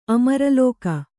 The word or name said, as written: ಅಮರಲೋಕ